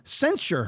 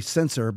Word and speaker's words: censure: Did everybody know there was a vote to remove Ilhan Omar off of her committees and to censure her? I always want to say censor, but it's censure, as I believe is how it's pronounced.